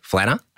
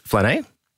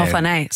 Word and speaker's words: Flané: So we've had flaneur, we've had flaneur, which is also a word in French. It's flané. – Oh, flané. So I mispronounced it as well. – Okay. And sometimes it can be flané as well. But we go with flané.